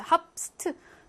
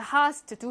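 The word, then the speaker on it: hast: 배 어디 갔어? 발음상 배를 그냥 생략했어. 합스트. 발음이 어렵죠? 그래서 하스트, 두 하스트 이렇게 쓰고요.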